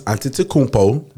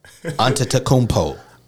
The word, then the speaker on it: Antetokounmpo: I'm starting Giannis Antetokounmpo. That's how you say his name for you bitch ass niggas that don't know how to pronounce shit. – Antetokounmpo.